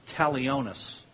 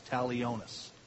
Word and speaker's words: talionis: Now, this is not in your notes, but there is a Latin phrase that is pronounced this way, lex, L-E-X. So lex means law. And the next word is talionis, and it's T-A-L-I-O-N-U-S, T-A-L-I-O-N-U-S, talionis.